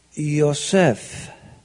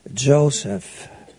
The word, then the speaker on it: Yosef: It is like Yosef. Here in the land of the West, you pronounce it probably like Joseph. But it's the same guy.